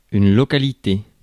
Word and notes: site: /saɪt/

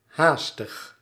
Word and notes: rash: /ræʃ/; [ræʃ]

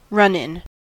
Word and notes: run in: /ɹʌn ɪn/

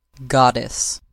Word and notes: goddess: American English

